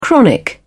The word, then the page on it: chronic: British English